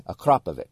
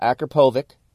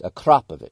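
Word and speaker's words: Akrapovič: That's how you say it, okay? Akrapovic. It's not Akrapovic. It's Akrapovic.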